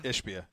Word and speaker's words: Ishbia: And then the owner, how do you pronounce it? Ishbia? – Ishbia, yeah.